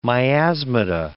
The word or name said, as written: miasmata